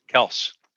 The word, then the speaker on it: Kelce: Kelce, I'm going to take over Nick Chubb. – Oh, yeah. Kelce's first round. – Kelce.